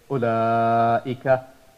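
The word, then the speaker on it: ulaika (أولئك): Another example, ulaika. So we do not pronounce the waw. We say ulaika.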